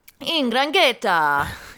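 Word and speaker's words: 'Ndrangheta: Also what happened this week, there were European-wide raids against the Italian N'Grangheta. – What? – N'Grangheta. I don't know how to pronounce it. – N'Grangheta.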